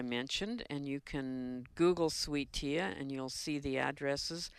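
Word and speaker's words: Sweet Thea: Sweet Tea, and that's spelled T-H-E-A.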